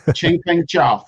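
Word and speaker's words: Changpeng Zhao: Chengpeng Zhao, right? – Chengpeng Zhao.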